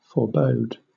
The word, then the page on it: forebode: British English